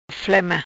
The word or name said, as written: Flema